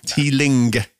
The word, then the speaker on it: Teeling: I only spelt it out because you pronounced it Teelin. Right, you dropped the G off the end of it with your native London tongue.